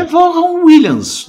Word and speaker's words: Vaughan Williams: Qual que é a pronúncia certa? Qual que você descobriu que é a pronúncia ah, certa? Ah, pra mim nome? é Vaughan Williams.